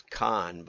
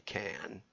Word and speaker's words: Cannes: I just saw a show where they went all in on how to pronounce Cannes. I thought it was con, but it's apparently can.